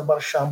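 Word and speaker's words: Barshim: říkal když mě budete říkat Baršám, budu opravdu velmi rád, protože je to eh, skutečně eh, tak, jak mé jméno zní.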